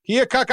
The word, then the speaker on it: Keokuk: Is that how you say it? Keokuk, Iowa? – Yep.